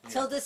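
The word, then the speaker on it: Tilda Swinton: Is a tie between We Need to Talk About Kevin, starring the wonderful Tilden Swinton. Hopefully I pronounced her name wrong. – Tilda Swinton, and Martin Scorsese's Hugo.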